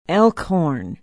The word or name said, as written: elkhorn